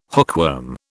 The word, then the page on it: hookworm: British English